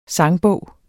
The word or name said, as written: sangbog